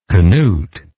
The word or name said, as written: Canutish